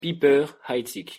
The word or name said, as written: Piper-Heidsieck